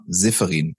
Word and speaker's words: Seferin: und zwar Vanessa Seferin.